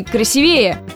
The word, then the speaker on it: красивее: Красивее или красивее? Сомнения в сторону. Красивее и не иначе. Скажу даже больше, в слове «красивее» ударение вообще неподвижное, а значит всегда падает на «и».